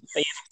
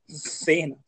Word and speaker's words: Thanos: Thanos. If they make it's not like you pronounce it like anus with a TH. It's not Thanos.